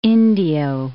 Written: Indio

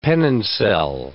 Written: pennoncel

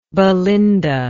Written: belinda

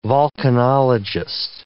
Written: volcanologist

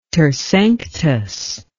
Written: tersanctus

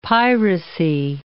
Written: piracy

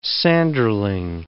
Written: sanderling

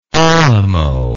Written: Alamo

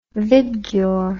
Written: vibgyor

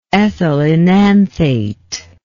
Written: ethyloenanthate

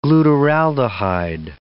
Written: glutaraldehyde

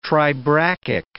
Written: tribrachic

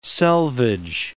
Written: selvage

